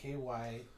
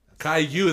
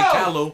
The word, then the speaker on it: Caillou: Right here it says pronounced KYYOO. – Caillou. That's just showing you how to. And so there's people saying Callow.